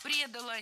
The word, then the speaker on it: предала: Его Галштинская гвардия не предала и готова была за него умирать. – Предала, неверно, правильно, предала. Ударение должно падать опять же на окончание.